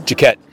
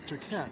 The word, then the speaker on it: Jaquette: How do you say Elijah's last name? – Jaquette. – Jaquette. – Yeah. – It's kind of like French or something.